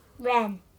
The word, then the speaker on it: Ren: His name's Kylo." – Ren. – Kylo Ren. Rhymes with Ben.